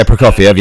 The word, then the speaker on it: Prokofiev: But, you know, we do have a nice Sergei, I can never pronounce it, Prokofiev. – Sergei Prokofiev, yep.